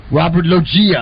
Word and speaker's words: Robert Loggia: Susan Blakely was his wife, and she was the daughter of a very rich man played by Robert Loggia, I think.